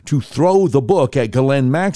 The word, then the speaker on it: Ghislaine: Also, of course, Epstein's longtime girlfriend and pimp, Ghislaine Maxwell, that's how you're supposed to pronounce it. It's spelled G-H-I-S-L-A-I-N-E, like Ghislaine or something. But the way you pronounce it, the S is silent, and it almost sounds like G-L-E-N-N.